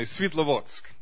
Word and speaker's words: Svitlovodsk: Jeremy pronounced it excellently, Svitlovodsk. Now, he's had a little bit of practice. If you don't speak Russian, that might be a little bit tricky for you.